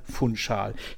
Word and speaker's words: Funchal: Also den Ortsnamen von Verein Maritimo, also Maritimo Funchal, spricht man mit SCH aus. Also Funchal, nicht Funchal, nicht Funchal, Funchal.